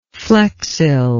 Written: flexility

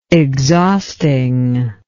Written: exhausting

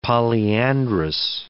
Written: polyandrous

